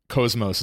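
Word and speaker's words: KOS-MOS: In Xeno's Saga, at least initially, Cosmos is how they say it.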